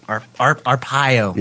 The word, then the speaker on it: Arpaio: Sheriff Joe Arpaio. Is that how you say it? – Arpaio. – Yeah, that guy.